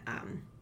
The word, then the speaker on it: I'm: 那第二个 ,I'm, 一样就是 I am 合起来你会念成 I'm。 I 念出来嘴巴闭起来。